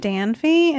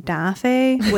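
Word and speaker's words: Danfe: But my favorite shop, which I found when I was so desperately looking for clothes, is called Dafe. It looks like it's spelled Danfy. And I told a Nepali friend that. I was like, oh my gosh, have you heard of Danfy? And she was like, I think you mean Dafe, which is the national bird of Nepal.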